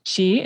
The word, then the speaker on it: qi: What is your understanding of, I think it's pronounced qi, it's Q-I is how it's spelled.